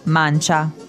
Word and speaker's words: mancia: The word in Italian is la mancia, like the man of La Mancha, but no. La Mancha, it's spelled differently. So just think about mancha, tip.